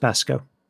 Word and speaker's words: Vasco: The other thing is Vasco should be pronounced Basco. – Oh, righto. I did not know that. – Because V's are pronounced as B's.